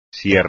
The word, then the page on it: sierra: espagnol